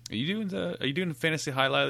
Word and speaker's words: Hialeah: Oh, he could, but it ended up in India somewhere, and he changed the face of some, you know, Hialeah franchise. – Oh, that's how we got our Slack hacked into. – You hanging out with some weird? How do you say that? – Hialeah.